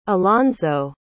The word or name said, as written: alonzo